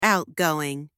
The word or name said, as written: outgoing